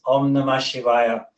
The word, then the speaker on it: Om Namah Shivaya: Om Na, Na, if you will go through the five syllables, Na, Ma, Shivaya. But when you put them together, the mantra is Om Namah Shivaya.